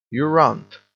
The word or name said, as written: you runt